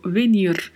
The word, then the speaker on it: venir: Atente-se para a pronúncia, o verbo venir. Tem aí a letra V com leve som de B e tem o vibrato do R ao final, né?